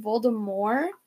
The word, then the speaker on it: Voldemort: You know, it's actually, his name's actually Voldemort. It's supposed to be pronounced Voldemort. – Yeah. – That, like, J.K. Rowling. People started saying the T. Just caught on.